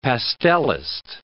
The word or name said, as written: pastelist